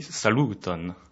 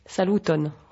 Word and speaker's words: saluton: Alors, tout d'abord, justement, pour se saluer, pour dire bonjour, on dit « saluton ». – Saluton. – Voilà. Donc, tu pourras remarquer qu'on met l'accent sur l'avant-dernière syllabe. C'est pour la beauté de la langue.